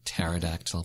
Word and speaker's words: pterodactyl: I like to say pterodactyl. That kind of helps me remember how to spell it. – Yeah. – The P is silent.